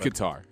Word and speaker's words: Qatar: Is it Qatar or Qatar? – I'm pretty sure it's Qatar. – I think it's Qatar. But, no, I mean, I think it's people pronounce it both ways. – It's Qatar, yeah. – Okay. Like a guitar.